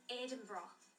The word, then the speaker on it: Edinburgh: The pronunciation of our capital city in Scotland. – Say it. – It is Edinburgh.